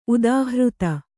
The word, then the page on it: ಉದಾಹೃತ: udāhřta